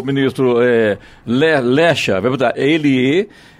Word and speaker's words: Lexa: Que é Lecha, né? Lecha e, Escreve L-E-X-A, né? Então a pronúncia. Eu não, não a conheço, também leria como Lexa, né? E não é. é o correto é Lecha, né?